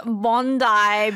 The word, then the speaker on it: Bondi: 크쌤, 본다이 비치예요. 본다이로 발음해 주세요. 호주인들은 웁니다. 저는 본다이로 했어요. – I apologize. Bondi Beach.